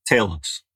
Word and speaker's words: Talos: Talos. – Talos.